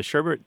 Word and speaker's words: sherbet: The sherbet thing pronunciation is so widespread that some places have, as you might expect, you know, where I'm going with this, have just given up and go ahead and spell it with that second R, even though it's not required.